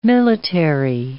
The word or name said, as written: military